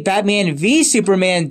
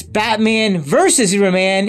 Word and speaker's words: Batman v Superman: So in Batman number 8, Batman v Superman Dawn of Justice, often pronounced as Batman versus Superman, it is just a single V like in a court case.